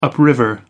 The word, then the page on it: upriver: American English